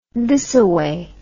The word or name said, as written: thisaway